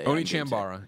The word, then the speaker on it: Onechanbara: Onechanbara. Onechanbara. – It's One, not Oni.